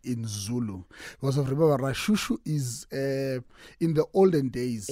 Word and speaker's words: Lashushu: It's written Lashushu, but pronounced Rashushu. – Why is that? Because of in Zulu, you don't have the R.